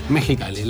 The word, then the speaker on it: Mexicali: Mexicali, la X es una J, se pronuncia.